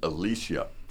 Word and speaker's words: Elesia: And today I'm coming to you with a young lady named Elesia.